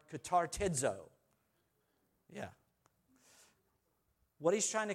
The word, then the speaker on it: katartizo: And the word comes from the same word that we find in Ephesians 4, from katartizid. I got to say this. Katartizid. That's what it is. Katartizid. You can laugh at me. You try it. K-A-T-A-R-T-I-Z, long O, D. Katartizo. I got it.